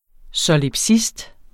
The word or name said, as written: solipsist